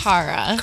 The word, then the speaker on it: Cara: You should say my name right. – How do you want me to say first? – It? – It's Cara.